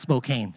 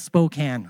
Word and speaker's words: Spokane: Now, it's not pronounced Spokane. A lot of Canadians say that. And people from the eastern states. It's Spokane, all right?